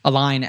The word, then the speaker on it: align: So align, it's spelled weird, right? It's L-I-G-N, but I like to think of it like L-I-N-E, line, that you're like getting two lines in parallel or something, you know, so that they're going in the same direction.